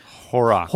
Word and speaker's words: Horrocks: Thomas Horrocks. – Horrocks. – Yeah. Horrocks. – Rhymes with the bleach. – Horrocks. – Horrocks. – There you go. – Horrocks. – That's it. – Clorox. Got it.